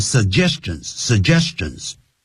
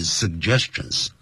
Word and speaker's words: suggestions: 你看我念这个 suggestions，它是有两个 G， S-U-G-G，所以第一个 G 给前面 sug，然后第二个句给后是 suggestions，好多人念成 suggestions，好，但是大部分的美语人士呢啊都会念成 suggestions。